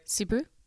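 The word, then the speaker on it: Suburu: Our friend Jeff Subaru. – Uh huh. – Did I say that right? – Subaru.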